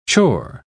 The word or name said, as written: chore